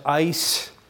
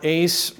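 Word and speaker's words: eis: Same Greek word, it's E-I-S, ice is pronounced, or ace, repented at the preaching of Jonah.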